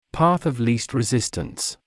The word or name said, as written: path of least resistance